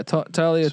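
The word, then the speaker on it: Taulia: Because Talia, is that how you pronounce it? – Yeah. Talia.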